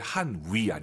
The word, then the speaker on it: 汉语: 중국어 발음하실 때 한위 아니고 와유 발음은 입술 동그랗게 말아서 한위.